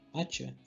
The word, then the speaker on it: Atia: É, a gente também teve um jogo que, bom, não definiu o nome ainda, mas estão chamando como Project Atia. Atia, assim que pronuncia.